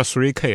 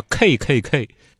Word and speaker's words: KKK: 三 K 党呢，在美国那边发音啊，它不叫三 K，不叫 three K 啊，叫 KKK。